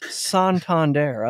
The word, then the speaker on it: Santander: There, it looks like, like it's, it looks like it's spelled Santander, like a yokel might pronounce Santana, but it's Santander.